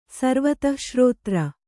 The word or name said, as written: ಸರ್ವತಃಶ್ರೋತ್ರ